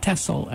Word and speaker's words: Texel: They don't grow in Europe, except on the island of Texel, right above, which is pronounced Texel, actually, above the Netherlands.